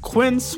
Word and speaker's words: Quince: To Today Explained's support today comes from Quince, which rhymes with since but is spelt with a Q-U.